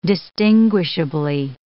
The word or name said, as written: distinguishably